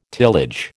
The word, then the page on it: tillage: American English